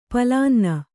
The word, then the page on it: ಪಲಾನ್ನ: kannada